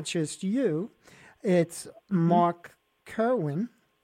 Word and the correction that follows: Kerwin: Carwin, yes. – How do you say that? – Mark Carwin. – Carwin.